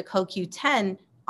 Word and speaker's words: CoQ10: And so having GG on board helps you to make the CoQ10 on your own, correct? And did I say that right? – You say that correctly.